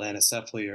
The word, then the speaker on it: anencephaly: You know, I think back to the death of our first child of a condition called anencephaly, or some pronounce it anencephaly.